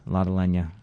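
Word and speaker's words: Lotte Lenya: Lata Lenya.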